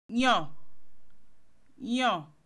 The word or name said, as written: choose